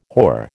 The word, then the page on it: hoar: American English